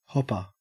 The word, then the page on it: hopper: Australian English